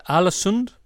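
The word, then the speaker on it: Ålesund: So your first club, am I pronouncing this right, Alesund? – Well done.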